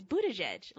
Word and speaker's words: Buttigieg: It's Buttigieg. Like, just don't look at how it's spelled. Just listen to how it sounds.